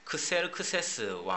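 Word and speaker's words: Xerxes: 사실 그 여러분이 더 잘 알기로는 그 영화 300에 나오는 크세르크세스 왕 그 이름으로 더 잘 아실 거예요. 그거는 그리스식 발음이거든요.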